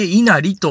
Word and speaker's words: 稲成: あれを書いて、稲がなると書いて、稲荷と読むんです。実はこのさっきから言ってる全国に一番数多い神社なんじゃないかという、この稲荷神社の中で、